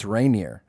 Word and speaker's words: Rainier: No, Rainier is the Texas pronunciation. But if you say that, they're like, it's Rainier. And I'm like, yeah, so I said Rainier. – Yeah, they're like adding an extra syllable in there.